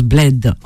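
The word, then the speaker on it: Blade: Ça s'écrit Blad, mais ça se prononce Bled en anglais.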